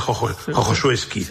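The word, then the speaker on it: Chochołowskie: Se escribe Chocholueski, pero se pronuncia Jojosueski.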